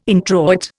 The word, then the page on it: introit: Indian